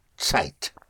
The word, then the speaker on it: Zeit: It's spelled Z or Z E I T. And uh, that is pronounced like a TS. Zeit.